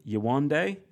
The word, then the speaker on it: Yewande: Um, and then I think Michael and Amber will be together, and then Callum and Yewande. Is that pr- pronunciation good? Yeah, sounds sounds good to me.